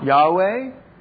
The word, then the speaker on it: Yahweh: And so, in some circles, it's pronounced Jehovah. And some people pronounce it, what's the other pronunciation? Do you know it? Yeah. Yahweh.